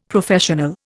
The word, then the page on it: Professional: Indian